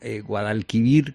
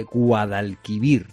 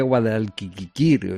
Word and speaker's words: Guadalquivir: Yo decía Guadalquiquir, o sea, porque ya no, ya me parecía que había mucha sílaba ahí, ¿no? – Claro.